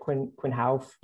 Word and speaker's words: Quinn Houff: Quinn Houff.